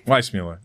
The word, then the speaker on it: Weissmuller: Weissmuller? – Weissmuller.